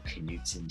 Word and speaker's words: Knudsen: Yeah, so pretty much any social media platform, / Daniel Knudsen DK. My last name is spelled KNUDSENDK.